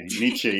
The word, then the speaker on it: Nietzsche: Like I know in the book you talk a lot about, I don't want to butcher his name, is it Nietzsche, yeah. Although even I'm probably butchering it in my own way, yeah.